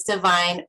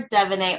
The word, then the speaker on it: Devine: Hi, my name is Divine, or Devonay, or Dev. I will respond to pretty much anything.